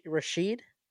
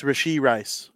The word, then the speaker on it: Rashee Rice: I don't love the receiving core, but it seems like Rashee Rice is kind of taking, or did I say his name right? Rashee Rice? – Is it Rashid? – No, it's Rashee Rice.